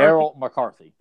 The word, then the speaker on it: Errol McCarthy: God, am I saying that right? Errol McCarthy? – Errol McCarthy. – Yeah, Errol McCarthy. I wanted to make sure I was saying the L.